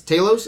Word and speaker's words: Talos: Let's start with uh, Ben Mendelsohn as Talos. Is that how you say his name? Yeah. – yeah.